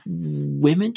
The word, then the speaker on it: Whimn: It's spelled W-H-I-M-N anyway. – Yes, I would assume it's pronounced women because it's Women's Content Network and it's spelt W-H-I-M-N, which is the With Her In Mind Network.